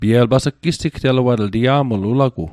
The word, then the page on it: sigteluatl: Mi'gmaq